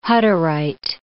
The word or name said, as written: Hutterite